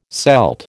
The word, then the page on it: Celt: American English